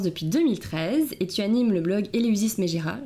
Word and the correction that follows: Megara: J'espère que je le dis bien. Megara. Megara, pardon.